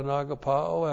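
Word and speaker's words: agapao (ἀγαπάω): you got the idea on agapao after 1 John? A-G-A-P-A long O.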